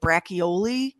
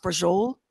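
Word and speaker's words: braciole: It looks like brachioli. – Yeah. – But it's pronounced brujol.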